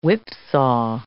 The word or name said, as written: whipsaw